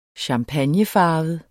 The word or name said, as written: champagnefarvet